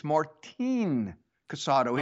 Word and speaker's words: Martin Casado: And of course, actually, it's Martin Casado. He likes his first name pronounced Martin.